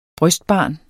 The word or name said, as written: brystbarn